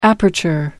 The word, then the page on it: aperture: American English